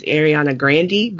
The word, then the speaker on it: Ariana Grande: Which apparently we've been saying her name wrong, is Ariana Grande, but what's done is done.